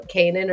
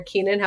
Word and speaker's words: Keinan: My name is Michelle Kienan, or Kienan, however you want to pronounce it.